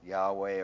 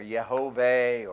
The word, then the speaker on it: Yahweh: Yahweh, which we call Yahweh. We pronounce it Yahweh or Yehovah or Jehovah.